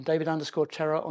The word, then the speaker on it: David Terrar: I'm at DT on X, I'm David_Terrar on Threads, or you can search for me on LinkedIn. Surname is spelled T-E-R-R-A-R.